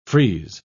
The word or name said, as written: frieze